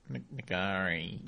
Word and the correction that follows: Ngaire: It's Nairi.